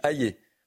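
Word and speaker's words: Hayer: Alors euh, déjà, il faut bien prononcer son nom. Elle s'appelle Valérie Hayé. On dit Hayé, parce qu'il y, a eu des questions.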